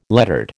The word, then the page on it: lettered: American English